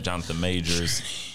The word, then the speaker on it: Jonathan Majors: But so Lovecraft Country and Misha Green, Journey Smollett is in it, Jonathan Majors. You got to say it like that.